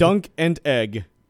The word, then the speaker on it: Dunk and Egg: Wait, hold on. It's called Dunkin' Egg? – Dunk and Egg. – Spell it. – Dunk, D-U-N-K. – This is a Game of Thrones spinoff? – And Egg, E-G-G.